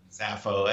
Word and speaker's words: Sappho: Sappho, S-A-P-P-H-O.